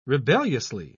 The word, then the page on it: rebelliously: British English